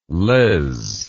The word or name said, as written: lez